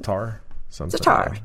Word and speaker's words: Sartre: Sartre. I can't pronounce it. – Sartre. – There you go. Sartre.